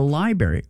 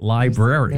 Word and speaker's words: library: But why are you calling it the library? That's how you say it. I said, no, you don't. You say it library. – There's an R in there, yeah.